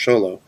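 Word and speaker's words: Xolo: It felt like watching a good comic book on screen unfold, James Gunn has said several times that I said Zolo, but he says his name is pronounced Sholo, that Sholo will return as Blue Beetle.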